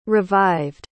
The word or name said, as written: revived